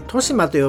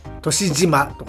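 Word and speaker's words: 利島: セスナ機と墜落っていうのは本当のセスナっていう会社が作ってたらしいんですけど、それも後で訂正されてますけど、まあもともこの時ヒゲはですね、リシマと書いてトシマと読むんですが、トシジマとかね紹介しておりまして、あの土地にね、無頓着ぶりを発揮しておりました。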